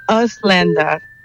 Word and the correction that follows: Ausländer: Casi. Me da permiso de echarle extraño la manita con la pronunciación. – Ok. – A ver, se dice Auslanda.